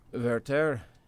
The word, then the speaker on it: Werther: And, you know, Werther, did I say that correctly? – You did.